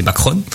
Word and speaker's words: Macron: Donc on le dit Macron en français. En italien, c'est marque italienne. Donc ça se dit bien comme vous l'avez prononcé, Macron.